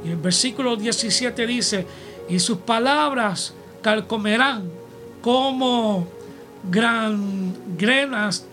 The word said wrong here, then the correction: gangrenas: amén, o perdón, este, ¿cómo se pronuncia esto aquí? Gangrenas, mejor dicho, de los cuales son Himeneos y Fileto.